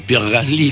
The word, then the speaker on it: Pierre Gasly: Que le jugó, Pierre Gasly, de que le jugó, te gustó la pronunciación. – Sí, ¿no? Es así, es de francés.